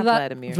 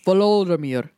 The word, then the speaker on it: Volodymyr: How do you say his name? Vladimir? Because it's not Vladimir. – Vladimir. – Volodymyr. – Volodymyr. – Yes.